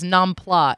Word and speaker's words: nam pla: Yeah, I think that the Vietnamese, and I'm sure I'm destroying the pronunciation, refer to it as nam pla, N-A-M-P-L-A. I'm pretty sure, but they don't refer. – It is. You are correct.